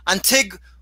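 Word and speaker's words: antigüedades: Okay, the only word that I really focus on a lot with the double dot in Spanish is antiguidades. Antiquity, but they got over the U, which means add a syllable. Antiguedades. No antiguedades. Antiguedades.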